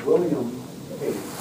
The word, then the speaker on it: William Pace: My name is William Pace. W-I-L-L-I-A-M-P-A-C-E.